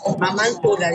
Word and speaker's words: Mamá Antula: Vamos a hablar ahora que está conectada vía Zoom de la parroquia Mamá Antulo. ¿cómo estás? Gracias por atendernos. – Buenas tardes, Mamá Antula, discúlpame. – Sí, Mamá Antula, ¿lo dije mal? – Había escuchado Antulo.